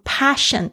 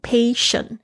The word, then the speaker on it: passion: And passionate young individuals, passionate 它是从 passion 这个字来，大家要特别注意一下你们 A 的发音，不要念成 patient， patient 会变成就是有耐心的。 Passion 这个 A 是那个就是舌头向下压，那个音标看起来像蝴蝶的那个声音。